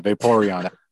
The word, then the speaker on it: Vaporeon: But like, since I like blue, so Vaporeon is that, I don't know if that's how you say it. Yep, it is. You got it right.